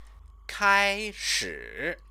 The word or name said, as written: kaishi